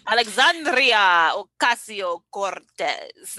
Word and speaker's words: Alexandria Ocasio-Cortez: I can't even say it right because my tongue is not letting me trill my R's. Okay. – Yes. – I took Spanish. Alexandria Ocasio-Cortez. Okay, yes. There we go.